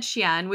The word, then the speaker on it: Shein: You said Shein, which is like, I'm pretty sure how it's actually pronounced,